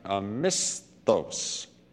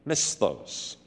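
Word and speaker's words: misthos (μισθός): And that word is pronounced something like misthos. Misthos.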